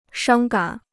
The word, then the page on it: 伤感: shāng gǎn